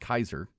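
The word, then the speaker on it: Kysre: Her name is spelled K-Y-S-T-E, but it's pronounced Kaiser.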